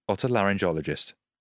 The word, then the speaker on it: otolaryngologist: Hard G or soft G? – I think it's got to be soft. – Otolaryngologist. Yeah, that sounds right.